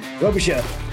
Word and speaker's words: Robichaux: Robichaux. Chad Robichaux.